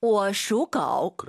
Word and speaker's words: 我属狗: 我属狗. – 그렇죠. 我 올라간 순간 틀린 발음이에요. 我属狗. 삼성이 마지막 글자만 올리지 마시고요. 영어 좀 할게요.